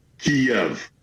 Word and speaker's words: Kyiv: M or is it Kyiv? Now, the Russian pronunciation is Kyiv. The Ukrainian closest approximation that we can do as non-Slavic speakers is Kyiv. So the Y ends at the first syllable.